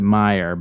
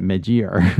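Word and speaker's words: Meijer: She pronounces it Meyer, but it's M E J IER or whatever. I pronounce it mid year.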